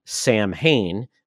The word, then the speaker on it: Samhain: And they created basically a pumpkin head type villain, which I thought was funny because they called him Samhain, Samhain. They got the pronunciation wrong.